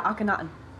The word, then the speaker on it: Akhenaten: I want to say Akhenaten's ghost? – Akhenaten.